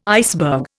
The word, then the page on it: iceberg: Indian